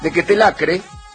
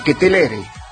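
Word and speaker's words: Ketelaere: Después vino el gol de Quetelacre, creo que lo dije bien, a ver, de Quetelere, el gol de Bélgica de cabeza.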